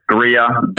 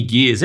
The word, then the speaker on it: Gears: Christian Hawkins and Timmy, is it Gears or Jeers? – Gears. – With a hard G? – Hard G. I get all sorts. I get Greer, Gear, Jeers, Greers.